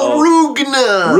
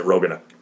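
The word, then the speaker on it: Roogna: Which I will say, I do subscribe to the Rugna pronunciation as a kid. And when I read this, the first couple, when I read this series, I always pronounced it Rogana.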